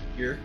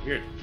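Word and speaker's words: Björk: I did learn that the name is technically pronounced Yerk, like the word jerk, but with a B in front of it. – Yerk.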